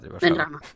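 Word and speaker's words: Benrahma: Benarama parece que está Benrama. – Siempre lo digo mal.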